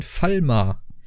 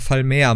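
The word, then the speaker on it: Falmer: Ähm, aber, und dann sind da noch die Meister von denen, denn die lieben Chauros wurden domestiziert von den Falma. – Oder Falmea müsste man mhm. das eigentlich aussprechen.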